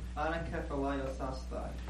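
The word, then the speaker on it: anakephalaiosasthai (ἀνακεφαλαιώσασθαι): But it's anakephalaiosasthai.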